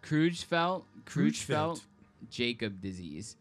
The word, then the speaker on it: Creutzfeldt-Jakob: Creutzfeldt-Jakob disease.